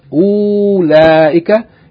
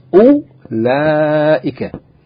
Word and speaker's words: أُولَٰئِكَ: Note that the wow in أُولَٰئِكَ. U, yani is, the wow is not pronounced. It's not pronounced أُولَٰئِكَ. It's pronounced أُولَٰئِكَ.